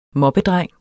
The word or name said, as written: moppedreng